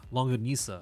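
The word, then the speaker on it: longganisa: That's how you say it? Shinganusa. – Shinganusa. – Shinganusa. The little sausage. – Longanisa. – Longanisa, yeah.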